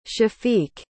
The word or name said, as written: shafiq